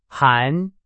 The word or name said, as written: 含